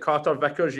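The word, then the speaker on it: Carter-Vickers: What do you make of Carter Vickers on loan? Is that how you say it? Carter Vickers, yeah, on loan from Spurs, centre half.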